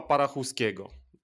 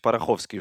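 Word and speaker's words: Parachouski: Czy ja dobrze wymawiam jego imię i nazwisko? Mm, myślę, że tak, chociaż z tego co słyszałem, on sam też y, chce czy pozwala, żeby mówić Parachowski, już tak bardziej spalszczając.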